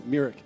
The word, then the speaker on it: Myrick: Am I saying that right, Pastor Roy? Myrick.